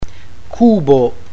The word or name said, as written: cubo